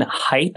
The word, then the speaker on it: Haidt: It's a book on moral psychology by a guy named Jonathan Haidt, I believe is how you pronounce his name. It's H-A-I-D-T, and it's called A Righteous Mind.